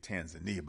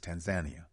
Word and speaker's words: Tanzania: Of course, it's, I used to say Tanzania, but it's Tanzania. That's how it is pronounced in Kiswahili.